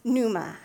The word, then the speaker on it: pneuma: In Greek, one of the words used for Spirit is pneuma. It's kind of strange. It's spelled with a what looks like a P at the beginning. So if you think of like pneumonia, it's that root.